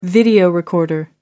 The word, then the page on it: video recorder: American English